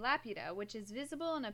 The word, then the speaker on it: Laputa: Laputa. – Laputa.